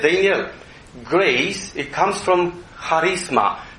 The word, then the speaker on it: charisma: But you write with C, but you pronounce it ha, charisma.